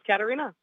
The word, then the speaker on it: Katharina: The H is silent. – I knew it. – So it's Katharina.